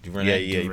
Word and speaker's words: DuVernay: But some pretty big news happened this week when we learned that the movie, which will be directed by Ava DuVernay, that's pronounced DuVernay.